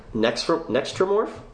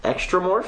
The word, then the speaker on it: Xenomorph: It's X-E-N-O-M-O-R-P-H. How do you pronounce that? – Is it? – Nextromorph? – Where do you see X. – This? Where do you see this? – Extramorph? – Which word?